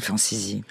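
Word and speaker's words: Francisci: ou alors Marcel Franciszki, je prononce bien ? – Marcel Franciszi.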